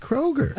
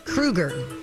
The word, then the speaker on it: Kroeger: we learned that Mr. Chad Kroeger does not prefer his name be pronounced Kroeger. – Oh! – Do you remember what the preferred pronunciation is? – Kroeger.